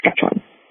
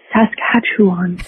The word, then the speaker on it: Saskatchewan: So everyone I know from Saskatchewan kind of slurs it into two-ish syllables, like Saskatchewan. You guys said it Saskatchewan, which is very precise and adorable.